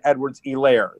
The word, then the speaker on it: Edwards-Helaire: But I've been told, actually, it's pronounced Clyde Edwards-Helaire, that the H is sort of silent.